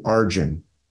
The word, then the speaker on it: Arjuna: The in the Bhagavad Gita or in the Mahabharata, there's a character named Arjun. Arjuna is how it's spelled. But Arjun, he's a warrior.